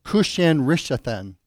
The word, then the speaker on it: Cushan-Rishathaim: The people that God had redeemed, the people God had bought, he now sells them into the hand of Cushan-Rishathaim. And that is how you say it.